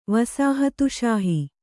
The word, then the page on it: ವಸಾಹತುಷಾಹಿ: vasāhat ṣāhi